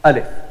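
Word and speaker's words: alif (ألف): This is the description of the pronunciation, alif. So you read it not like a, you read like alif.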